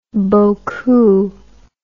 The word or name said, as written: bokoo